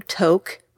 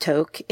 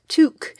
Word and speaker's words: toque: it's not pronounced toque. It's a French word pronounced toque. So the hat is actually a toque.